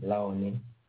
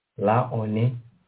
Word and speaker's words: Laone: L-A-O-N-E, Launie. – L-A-O-N-E. E? – Yeah. – La, say it. – Launie.